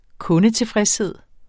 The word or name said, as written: kundetilfredshed